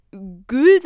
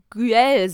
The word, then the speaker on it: Güell: Also, dass man nicht Gül sagt, sondern halt Güel sagt.